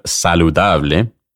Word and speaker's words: saludable: The Spanish word saludable, when pronounced with an English accent, sounds similar to saladable.